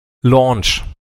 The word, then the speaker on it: Lounge: Lounge ist L-O-U-N-G-E. – Richtig. – Hier, Launch, Aussprache, Launch. Eindeutig, Google sagt's mir.